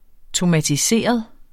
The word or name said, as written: tomatiseret